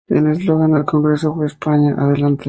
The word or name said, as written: eslogan